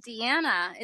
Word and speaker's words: Diana: Well, I hear Helena calling you Deanna. Is it Deanna or is it Diana? – It's D-I-A-N-A, but it's the Spanish pronunciation, which is Deanna.